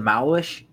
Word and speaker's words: Malwish: It's the Malwish? Is that what you said? – Yeah, is that how you pronounce it? – Yeah.